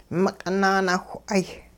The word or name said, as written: miq'it naanawh'ay